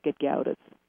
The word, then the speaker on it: Gedgaudas: It's spelled Gedgaudas, and it's pronounced Gedgaudas.